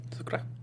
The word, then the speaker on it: Zurcaroh: But if I would say Shin, I would say Zucchera. Is that how you say it? – Zucchera.